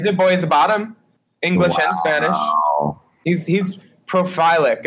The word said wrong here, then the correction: prolific: Is that how you say it? – Prolific. – Prolific. That's it.